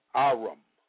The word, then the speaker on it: arum: The Hebrew word for subtle is arum, pronounced Aram,